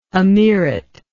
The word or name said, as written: amirate